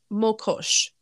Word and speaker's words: Mokosh: And her name is pronounced Mokosh, also known as Mokosh, Mokosha, and Mokoj.